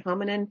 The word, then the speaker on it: hominin: Is it hominin? Is that how you say it? Hominin? – Hominin, yes.